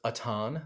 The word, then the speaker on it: Atan: It is pronounced Atan. – Atan. – Like a baton without the T.